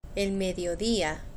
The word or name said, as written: el mediodia